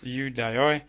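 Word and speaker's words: Ioudaioi: Hoi Yodoi, literally pronounced Yudaioi, appears 151 times in John and Acts, often referring to the enemies of Jesus.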